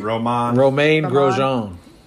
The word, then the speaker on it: Grosjean: But Roman, is it Grosjean? – Romain Grosjean. – Grosjean, okay, yep, there we go.